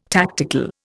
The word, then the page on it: tactical: Indian